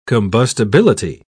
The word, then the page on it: combustibility: British English